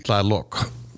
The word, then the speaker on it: Tlaloc: It's spelled T-L-A-L-O-C. Tlaloc.